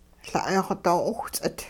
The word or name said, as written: La'ay-xw doo-'owhts'it